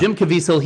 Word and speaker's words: Caviezel: Jim Caviezel? – Yeah. So yeah. Jim Caviezel, he played Jesus, Passion of the Christ.